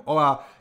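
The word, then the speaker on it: hola: holá má H na začátku, jo, ale nevyslovujeme, je to jenom ola, nějak se s tím poradí, dělej s tím, co chceš, je mi to jedno, prostě to je hotovo, bum, čau, nazdar, jo.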